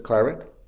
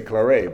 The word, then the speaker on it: Claret: I think that's how he pronounces it, Claret. Because he's Spanish, and if it was French, you'd say Claret, but he's not French.